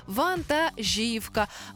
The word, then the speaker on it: вантажівка: А правильна відповідь – наголос на літеру І. Вантажівка. Вантажівки і багато вантажівок.